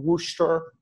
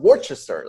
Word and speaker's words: Worcester: I've visited them in Worcester.